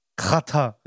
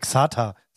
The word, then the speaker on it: Xatar: Chata. Rest in peace, wirklich? Bruder. Ich glaube ja, man sagt doch nicht Xata. Das heißt Ratter.